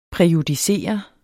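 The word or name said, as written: PRÆJUDICERE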